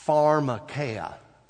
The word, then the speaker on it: pharmakeia: If you go to the Greek New Testament and pronounce this word, this is how you would say it. Pharmakeia.